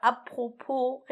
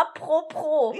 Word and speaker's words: apropos: Kannst du es mal apropos richtig aussprechen? Apropos. Du sagst immer apropos. Ja, apropos. Wie pro. Von pro und contra.